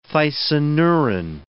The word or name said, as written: thysanuran